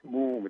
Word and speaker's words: Mumm: Moom, it's spelled M-U-M-M for those who don't drink champagne, and you may want to try it because it is fantastic.